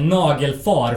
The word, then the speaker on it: Nagelfar: Sen eh, följdes det av eh, Nagelfar från Umeå. Som, som är ett gammalt... – Hur uttalar man det? det nagelfar? – Ja, precis.